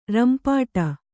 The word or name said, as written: ರಂಪಾಟ